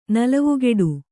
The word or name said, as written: ನಲವುಗೆಡು